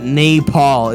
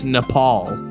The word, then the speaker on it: Nepal: Fuck, it was Nepal. Not Nepal, it's Nepal.